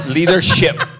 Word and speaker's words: Leadership: Le he tenido que ayudar a pronunciar la P. Leadership. Porque, a ver, porque cuando comenzó decía mala palabra.